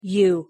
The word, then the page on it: u: English